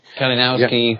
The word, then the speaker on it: Kalinowski: Is that how you pronounce it? Kalinowski? – Kalinowski, yep.